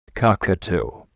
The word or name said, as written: cockatoo